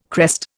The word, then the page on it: crest: Indian